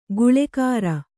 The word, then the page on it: ಗುಳೆಕಾರ: kannada